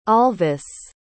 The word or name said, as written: alvis